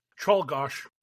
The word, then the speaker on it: Czolgosz: Leon Czolgosz. His name is very tricky. – Yes, it is. I was going to say, I don't know that one. The last name. How do you say it? – Czolgosz.